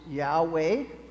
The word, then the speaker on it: Yahweh: Scholars added vowels, and so we pronounce it Yahweh for convenience sake, but really it's unpronounceable.